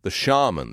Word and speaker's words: shaman: Let's talk about Matt Gaetz just for a moment, because you posted this rather brilliant clip of the shaman, the famous January 6th shaman or shaman. How do you say that? How do you say it in Florida, Ron? – Shaman. – You say shaman.